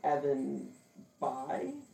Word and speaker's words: Bayh: Evan Bayh.